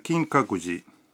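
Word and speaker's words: 金閣寺: Supongamos que queremos ir al Kinkakuji, un templo de Kioto. La pronunciación es Kinkakuji. Kinkakuji.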